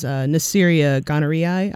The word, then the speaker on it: Neisseria gonorrhoeae: So the causative agent of gonorrhea is Neisseria gonorrhoeae, I believe is pronounced. – Yes. Yes.